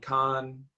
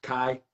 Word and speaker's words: Kai: Charlotte Flair versus Leilani Kai. And I said that wrong. Kai.